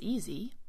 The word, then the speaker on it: easy: And remember, the S in easy and easier is pronounced like a Z or a Z.